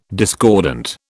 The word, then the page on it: discordant: British English